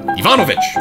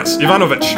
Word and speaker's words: Ivanovich: Anyways, when you combine those three things, you get Yemelian Inovich Pugachev. – Ivanovich. – Ivanovich.